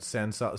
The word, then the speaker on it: San Salvo: So everyone go check out San Salvo. Spell it for everyone. – S-A-N space S-A-L-V-O. – Just as it sounds.